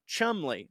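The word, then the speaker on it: Cholmondeley: In case no one got back to you on it, my friend put this out, and he says that Chalmondeley is pronounced Chumley.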